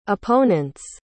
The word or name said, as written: opponent's